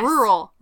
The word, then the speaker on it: rural: There's two R's. Do you pronounce the second R? – Yes. – Rural. – Rural. – Rural. – There you go.